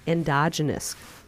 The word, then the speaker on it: endogenous: called endogenous risk.